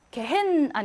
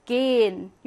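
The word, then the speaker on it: gehen: 강세가 여기 앞에 와, 그러면 강세 있는 모음 뒤에 하니까 하는 발음하지 않고 앞에 있는 L을 길게 만들어줘서 게헨 아니고 어떻게 발음해? 게인 이렇게 발음을 한다는 거죠.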